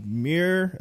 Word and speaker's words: Mir: Yeah, and it's coming to Netflix, and the animation studio that will handle it will be from Studio Mir.